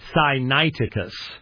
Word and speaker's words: Sinaiticus: It's not natural to say Sinaiticus. It's just natural to throw almost a leveling vowel in there. It's really interesting, but Sinaiticus.